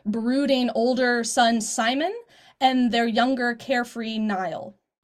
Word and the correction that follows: Niall: Neil. – Neil? – Neil. I said that wrong.